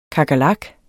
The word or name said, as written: kakerlak